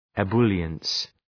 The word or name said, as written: ebullience